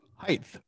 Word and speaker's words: height: It's H-E-I-G-H-T. – Okay, exactly. G-H-T. It ends in a T. The G-H is silent. Height.